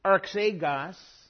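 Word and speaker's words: Arxegos (ἀρχηγός): Arxegos, with the emphasis on the last syllable there.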